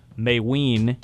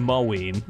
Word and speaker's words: Mayween: I was pronouncing it wrong. This is just going to kill me until Mayween has been here long enough. McCall Mayween. I was saying Mowien.